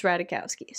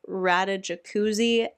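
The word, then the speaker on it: Ratajkowski: And the internet said that it's Radajowski. So that's what we're going with. Morgan could maybe try calling her that before jumping to Radajacuzzi. And then Paul is also calling,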